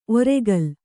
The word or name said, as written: ಒರ್ಕಲು